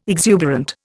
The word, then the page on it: exuberant: Indian